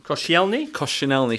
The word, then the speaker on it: Koscielny: I know they've bought Laurent Koscielny, I don't know how to pronounce that. – Koscielny from Lorient.